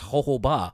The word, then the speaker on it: jojoba: It is actually pronounced jojoba. I've been pronouncing it wrong all these years because it is spelt with J's, but you pronounce the H.